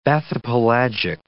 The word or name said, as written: bathypelagic